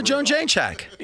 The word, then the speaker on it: Jędrzejczyk: But the woman that Zhang just fought, the Polish fighter, Joanna Jędrzejczyk, that one, don't even try. I don't even know if I'm saying it right. – Yeah, that's Joanna Jędrzejczyk.